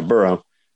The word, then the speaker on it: Burrow: So, and then you have the, you know, two old fashioned quarterbacks and Trevor Lawrence versus Joe Burrow. Did I say that right? Yeah, Burrow.